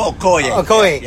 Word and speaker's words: Okoye: I always mispronounce Okoye, for whatever reason, Okoye, yeah.